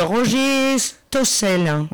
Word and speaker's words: Stossel: Et c'est la salle des fêtes Roger Stossel. – Voilà. – C'est comme ça qu'on prononce? – Oui, exactement.